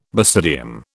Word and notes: basidium: British English

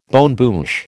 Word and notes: bonne bouche: American English

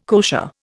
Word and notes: kosher: Indian

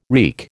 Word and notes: wreak: American English

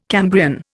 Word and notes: Cambrian: Indian

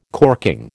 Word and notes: corking: American English

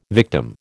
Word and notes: victim: American English

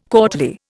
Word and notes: courtly: Indian

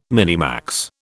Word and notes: minimax: British English

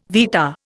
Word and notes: vitta: Indian

